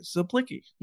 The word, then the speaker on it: Zaplicki: Silent C. Zaplicki.